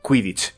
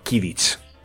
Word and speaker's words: Quidditch: Y que, por ejemplo, en Harry Potter se dice Quidditch y no Kidditch.